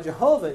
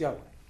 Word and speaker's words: Yahweh: And that's why Jehovah is actually not even a correct way to say it. It's Yahweh.